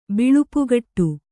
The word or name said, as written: ಬಲ್ಪುಗೆಡು